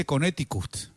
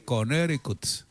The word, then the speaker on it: Connecticut: Otra cosa que yo aprendí ahí, porque estuve en la zona, en Denver, es, no se dice Connecticut, se dice Connecticut. La T suena como R, Connecticut, Connecticut.